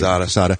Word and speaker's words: Quesada: They've got the evening that I saw Kevin do with Stan Lee and Joe Quesada. Quesada, how do you say it? – Quesada.